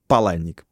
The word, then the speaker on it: Паланник: Поэтому Чак Паланник просто. Э, автор таких романов, как э, «Бойцовский клуб». Правильное произношение с ударением на первый слог.